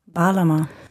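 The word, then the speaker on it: Bağlama: Ich habe vorhin das Instrument falsch ausgesprochen, das er hier spielt. Das ist eine türkische Laute. Und wie sagt man das nochmal richtig? Balama. Eine Balama, also ein Instrument, das Sie in den letzten Jahren öfter gehört haben, nehme ich an.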